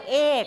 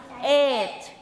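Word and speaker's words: eight: เราจะออกเสียงว่าเอกซึ่งเหมือนกับคำว่าไข่ซึ่งไม่ใช่ต้องออกเสียงว่าเอทไม่ใช่เสียงกอไก่นะคะเอทนะเอทไม่ใช่เอก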